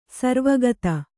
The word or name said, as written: ಸರ್ವಗತ